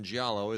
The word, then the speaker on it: giallo: Steve Gale writes us and says, quick note, the G in giallo is pronounced like the letter Y. The word for Italian, the word is Italian for yellow.